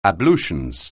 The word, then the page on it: ablutions: anglais